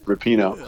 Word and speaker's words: Rapinoe: I'm probably saying her name wrong. I think it's Rapinoe.